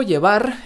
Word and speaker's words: llevar: Llevar con V pronunciada con B, porque en español el fonema V no existe.